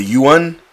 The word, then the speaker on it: yuan: which the yuan, is that how you pronounce it? – Yeah. – Okay. I get it confused with the yen.